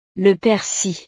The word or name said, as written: le persil